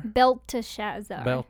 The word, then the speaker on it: Belteshazzar: Belshazzar. – Belteshazzar. – There was one without the T. There's a Belshazzar. – Yeah. – But he was another king.